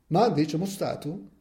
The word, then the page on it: Mandi: frioulan